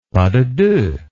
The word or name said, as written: pas de deux